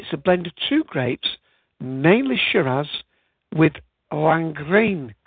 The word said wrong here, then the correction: Lagrein: Now, I'm not sure if I'm pronouncing that word correctly. It's a grape that's new to me. It's Lagrein.